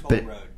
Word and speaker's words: Towelroad: Maybe someone can tell me how to pronounce this website. It's spelled T-O-W-E-L-R-O-A-D. Towel Road. – Toll Road. – What is it? – Toll. – Toll. That's what I thought.